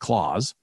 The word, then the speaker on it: Claes: And his first name is spelled C-L-A-E-S, but we're pronouncing it Claus.